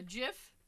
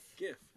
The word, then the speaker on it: GIF: GIF is a J, like the peanut butter. – Comment below your preferred way. – I say GIF, but. All right, go on. She posted a GIF.